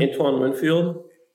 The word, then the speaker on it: Antoine Winfield: I think Emma's a Tampa fan with Anthony Winfield. Is that how you say his name? – Antoine Winfield.